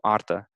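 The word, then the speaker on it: artă: So arta. So arta. So that last sound is a. – Arta. – Yeah, yeah. Maybe this isn't the best example because the emphasis is actually on the first syllable.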